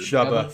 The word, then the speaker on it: Chuba: Chubba. Thanks, it rhymes with Hubba Bubba.